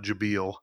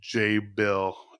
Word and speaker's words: Jabil: It's not Jabil, uh, it's J. Bill, James and Bill.